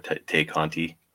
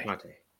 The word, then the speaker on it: Tay Conti: Obviously, have we confirmed that it is pronounced Ty Conte or Tay Conti? – It's Ty. – Ty. Ty Conte.